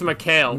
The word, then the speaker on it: McHale: But I, I could see Patrick McCall, McCall, McCall, right? I'm pronouncing his name right, McCall. I think it's McHale. McHale. I could see him using the Wizard of Oz as an inspiration because it's it's a little obvious.